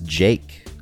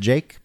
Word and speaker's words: Jake: My name is Jake. – Hi, Jake. Nice to meet you. – You know why my name is Jake? Because it rhymes with fake.